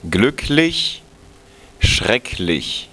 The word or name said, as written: schrecklich